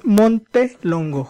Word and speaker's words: Montelongo: Porque luego me preguntan como 2-3 veces si pronunciaron bien el apellido. Y es Montelongo, junto.